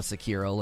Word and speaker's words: Sekiro: Just brutalize the pronunciation so badly that people ask you to go back to the generic American pronunciation. Yeah. Sekiro. Just call it Sekiro. Stop.